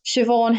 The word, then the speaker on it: Siobhan: So Robert's girlfriend, in air quotes, Siobhan Henderson, which can I say is spelled S-I-O-B-H-A-N, Irish for Siobhan.